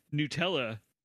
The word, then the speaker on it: Nutella: Apparently, it is pronounced Nutella, and nobody told anybody in America that until recently.